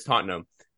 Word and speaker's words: Tottenham: Tottenham. I don't know why. Is that like, I think I might be an American thing because I've heard a lot of Americans say that same thing, but it's Tottenham.